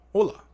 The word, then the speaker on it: hola: Oh, hola. Isn't that hola? Or is that what that... Oh, you just don't pronounce the H because it's...